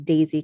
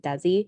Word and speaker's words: Desi: I know it's Daisy because someone told me it's, I was saying it wrong when I said Desi.